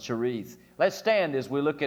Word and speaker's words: Cherith: We often pronounce it Cherith, but it's literally pronounced Cherith.